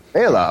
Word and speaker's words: Bela: And then I watched it multiple times, and I started watching, you know, as an actor, watching it, like, what Bella, is it Bella or Bela? – Bela. – Oh, so I've been saying his name wrong, like, like I don't think, like, he minds.